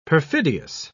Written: perfidious

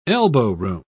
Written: elbowroom

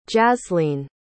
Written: jazlene

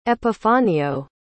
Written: epifanio

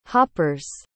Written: hopper's

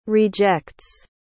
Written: rejects

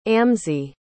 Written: amzi

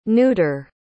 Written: nudar

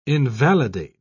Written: invalidate

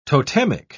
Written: totemic

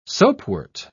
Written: soapwort

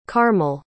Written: carmel

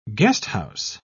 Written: guesthouse

